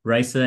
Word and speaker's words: Raissa: She said the Raissa as in like Rice-a-Roni is how she, uh-huh.